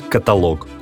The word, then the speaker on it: каталог: В разговорной речи мы нередко слышим «каталог». Знаете, так говорить неправильно. Литературной нормой считается только «каталог».